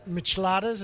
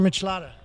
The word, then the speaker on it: michelada: We have now made our micheladas. Is that how you say it? Michelada? Michelada? – Like enchilada with other hands.